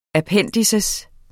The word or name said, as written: appendiks